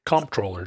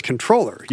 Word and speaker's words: Comptroller: And we wondered whether or not the Comptroller General pronounces it comptroller or controller. And David can confirm that the Comptroller General of the U.S. is the comptroller with the hard MP.